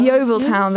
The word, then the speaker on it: Yeovil: I think it's Yeovil Town. – Yeovil Town, yeah.